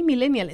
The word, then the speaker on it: millennial: Pero pues, oye, fíjate que aquí se pronuncia como una palabra grave que termina en L, por lo tanto debería llevar acento. Ah, pues sí, millennial.